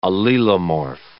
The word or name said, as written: allelomorph